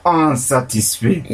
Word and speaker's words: unsatisfait: F-A-I-T. Unsatisfait. – I-T. So, unsatisfait. – Yes, unsatisfait.